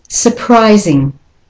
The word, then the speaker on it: surprising: And the next word is surprising. So the UR there becomes a schwa.